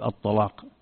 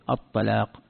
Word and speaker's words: الطلاق: مثلا لما يأتون عند كلمة الطلاق، إذا نطقت أحيانا بعضهم يفخم اللام يقول الطلاق، وبعضهم يرققها يقول الطلاق، هذا قالوا حرف، هذه حروف تعتبر حرف من حروف من الحروف، حرف من يعني الحروف السبعة، وهكذا.